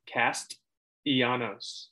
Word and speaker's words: Castellanos: Castellanos. – That's the same exact thing. – You don't pronounce the T.